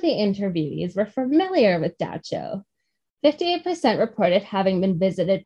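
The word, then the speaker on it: dab tsog: When asked about common nightmares, men and women described a figure called Da Cho. Luckily, they told me how to pronounce that one because it does not look like that. It looks like Dab Tasog.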